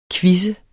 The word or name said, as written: quizzende